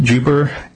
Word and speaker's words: Jubber: Yes, it's the name of the case is Page, P-A-I-G-E, versus, I believe it's pronounced Juber, J-U-B-B-E-R.